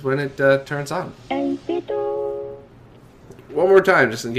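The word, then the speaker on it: 8BitDo: So, pronouncing it 8-bit do is probably, you know, it is not probably, it's definitely the right way.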